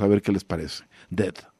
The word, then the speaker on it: Dehd: Se llama Dead, se pronuncia como muerto aunque se escribe diferente, D-E-H-D.